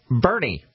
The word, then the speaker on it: Boerne: B-O-E-R-N-E. Pronounced Bernie. – Bernie. – And then there's another town, I think it's U-E-N-E, that's pronounced Green.